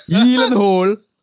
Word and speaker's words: Gyllenhaal: Whose real surname is pronounced Gyllenhaal. – Is it really? – Yes. Gyllenhaal.